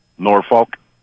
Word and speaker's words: Norfolk: How do you pronounce the Virginia one? How do the locals pronounce it? – Norfolk. – Norfolk.